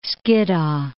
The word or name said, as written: Skiddaw